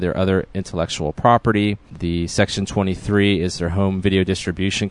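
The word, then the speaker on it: Section23: It's pronounced Section 23, despite the odd spelling.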